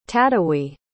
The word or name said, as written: tadewi